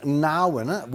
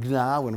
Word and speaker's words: Gnarwin: We move on to Narwin with a silent G. It's otherwise be called Gnarwin Road in Carnegie.